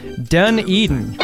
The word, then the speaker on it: Dunedin: Oh, he gave me a pronunciation key. It's pronounced Dunedin.